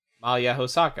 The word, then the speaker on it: Malia Hosaka: Say that name. – Malia Hosaka? Malia Hosaka. – That's what I thought. Yeah, I said that right. Yeah, I said it right. – Yeah. I'm not the authority on Japanese names, but I'm pretty sure that's how you would say it.